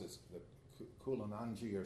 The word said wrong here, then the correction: Kundalini: or something like that. It's the experience in yoga or in Hindu. In Kundalini. – Huh? – Kundalini.